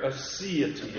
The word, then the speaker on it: aseity: Aseity is how it's pronounced.